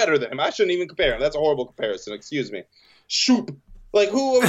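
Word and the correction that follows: Schoop: It's Scope, but Shoop is funny.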